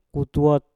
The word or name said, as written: kutwot